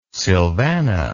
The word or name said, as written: silvana